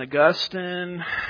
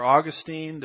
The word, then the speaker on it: Augustine: But Origen and Augustine, or Augustine, depends on which syllable gets the emphasis there, they would take that prophecy and say, oh, that doesn't mean that.